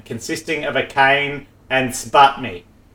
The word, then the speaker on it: SBATMI: So it says S-B-A-T-M-I, so I'm just saying that's S-P. Pronounced sputmi.